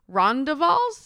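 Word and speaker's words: rondavel: We didn't get to experience the brand new rondavals.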